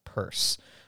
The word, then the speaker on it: Pierce: And as they are the owners of the property, I guess it is Purse, mm-hmm. not like P-U. I think it's P-E, Purse. – It's P-I-E-R-C-E. – Yeah, so it's not Pierce like to Pierce, it's Purse.